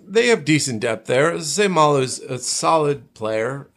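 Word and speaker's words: Seumalo: Is Isaac Samalo? – Yeah. – Good pronunciation.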